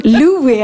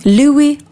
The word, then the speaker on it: Louis: var först på Louis, Louis heter han, man uttalar inte s Louis Armstrong, Armstrong.